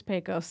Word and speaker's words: Pecos: Well, this morning we spent a significant amount of time deciding whether it was Pecos pupfish or pronounced a different way, and it is Pecos, so it is.